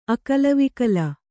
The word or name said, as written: ಅಕಲವಿಕಲ